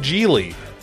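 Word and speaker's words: Gigli: That's right, it's the Bennifer mob romantic comedy kidnapping action movie called Geely. It rhymes with really, like really, really bad.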